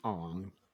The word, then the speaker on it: Aang: But I will say, as someone who did take a quarter of Japanese in college, the appropriate pronunciation would be Ong.